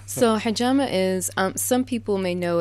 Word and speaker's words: hijama: So the hijama, mm-hmm. – am I saying that right? – Mm-hmm. Hijama. Okay.